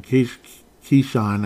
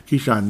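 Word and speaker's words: Keisean: But I guess it didn't matter because they had somebody named Nixon, Keyshawn, I guess it's pronounced, even though it doesn't, it's spelled differently, but you can tell you can pronounce it the same. Keyshawn Nixon, 105 kick return.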